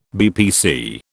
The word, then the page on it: BPC: British English